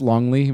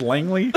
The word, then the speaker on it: Langley: By the way, is this Longley, Virginia? Longley? – Ma'am, it's pronounced Langley.